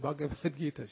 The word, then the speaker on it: Bhagavad Gita: And another lady, older woman, living in Louisiana, she said, I got this Bhagavad Gita. She didn't know how to pronounce it. Can you help me? How do you pronounce this? B-A-G-A-V. I said, Bhagavad Gita.